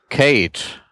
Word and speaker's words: Cade: John Frederick Joseph äh, Cade wird er wahrscheinlich ausgesprochen, also C-A-D-E geschrieben, war ein Australier.